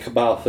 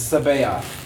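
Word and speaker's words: C'baoth: Yeah, I would pronounce it Kabaoth as well. – Yeah, but it's Sabaoth. – Completely unintuitive. – Zahn even said, if I thought so many people would have trouble pronouncing it, I would have spelled it differently. – It's funny. – With a damn S.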